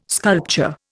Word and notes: sculpture: Indian

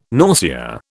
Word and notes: nausea: British English

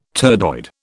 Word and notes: turdoid: British English

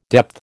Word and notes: depth: American English

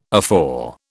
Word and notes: afore: British English

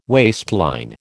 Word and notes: waistline: American English

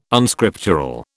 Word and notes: Unscriptural: British English

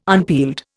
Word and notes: unpeeled: Indian

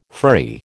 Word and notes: furry: American English

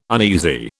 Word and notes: uneasy: British English

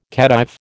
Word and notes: Khedive: American English

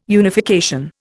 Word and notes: unification: Indian

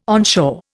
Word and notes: onshore: Indian

